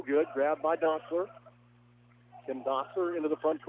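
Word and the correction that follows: Dotzler: Excuse me, Tim Dotsler. You pronounce a long O.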